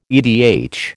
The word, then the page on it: edh: American English